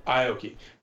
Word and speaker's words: Aoki: how do you say it again? – Aoki.